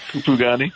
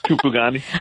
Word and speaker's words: Kupugani: Am I saying it right now? Camp Kup, say it again for me. – Yeah, sounds as good as the Zulu guy, Camp Kupugani. – What did you say, though? How do you say it? – Oh, yeah, like that. Kupugani.